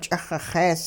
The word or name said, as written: ch'ixixees